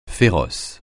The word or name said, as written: féroce